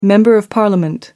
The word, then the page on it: member of parliament: American English